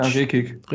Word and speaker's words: Rekik: Wir haben die, den Neuzugang, den Rekik, oder wie wird der ausgesprochen? Rekic? Ja, Rekik. – Rekik.